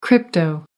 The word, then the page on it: crypto: American English